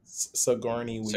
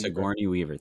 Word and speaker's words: Sigourney Weaver: I hope I pronounced her name right, but Sururian Uyghur. – Sigourney Weaver. – Sigourney Weaver, thank you.